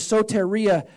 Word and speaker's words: soteria: Salvation, the Greek word is soteria, and I don't know if I'm saying it right, but it's spelled soteria, S-O-T-E-R-I-A, and here's the definition.